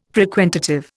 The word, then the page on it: frequentative: Indian